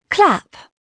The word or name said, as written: clap